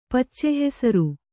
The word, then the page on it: ಪಚ್ಚೆಹೆಸರು: Kannada